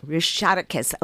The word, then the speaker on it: Rushatakis: Rushatakis. Okay, for all you programmers who struggled so hard to know how to pronounce that name, it's actually quite phonetic. Rushatakis is performing tonight at the Voodoo Room.